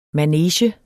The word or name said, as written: manege